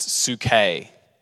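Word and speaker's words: suke (ψυχή): And the word for soul in the Greek was the word psuche. Actually, it's pronounced suke, suke, cool word.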